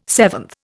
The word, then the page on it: seventh: Indian